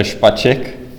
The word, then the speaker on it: Špaček: Could you tell our millions of listeners the proper Czech pronunciation of your name? – It's Petr Špaček, but I don't care because I'm going to a lot of conferences, so, you know, I'm used to all sorts of pronunciations.